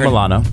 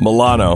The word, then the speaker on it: Milano: Bette Midler has joined Alyssa Milano. How do you say her? – Milano. No S's in there. – Yeah. Alyssa Milano. – There's some in Alyssa, but yeah. Not in Milano.